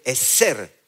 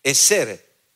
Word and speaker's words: ezer: Entonces, cuando yo veo, voy al hebreo y me dice que la palabra ayuda es una palabra en hebreo que se escribe eser, E-Z-E-R, eser.